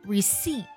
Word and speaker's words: receipt: 第二个单词，收据，它本身的拼作 receipt，但是它的音标呢和它的发音是 receipt，字母 p 是不发音的，不是 receipt。